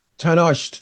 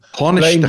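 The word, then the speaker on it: Tánaiste: Michael Martin, the Tannaist, if that's how you pronounce it, blamed UK... Tannaist, thank you. – Tannaist.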